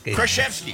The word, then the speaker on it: Krasinski: Jack Krasinski. What? Did I pronounce it wrong all the time? – Krasinski. Yeah, there you go.